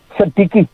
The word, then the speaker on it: Siddiqui: Siddiqui.